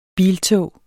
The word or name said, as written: biltog